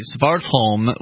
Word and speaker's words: Svartholm: Svartholm was, that's how you, in my opinion, how you pronounce his last name,